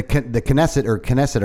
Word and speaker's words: Knesset: They're the Knesset, or how do you say it? – That's it.